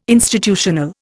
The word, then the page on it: institutional: Indian